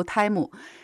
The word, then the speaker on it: Temu: 唐玉，好的，那接下来呢，再给大家带来一个消息，就是有一个中国的非常发展迅速的零售商叫做泰木，他呢在最近遭到了欧洲消费者组织的投诉。那这个泰木呢，它的拼读应该是 TEMU 哈，大家怎么读的都有，我们这里就读泰木。